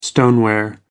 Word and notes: stoneware: American English

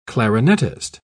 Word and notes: clarinettist: British English

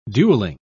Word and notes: duelling: British English

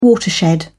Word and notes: watershed: British English

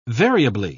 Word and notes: variably: British English

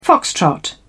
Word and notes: foxtrot: British English

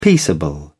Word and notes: peaceable: British English

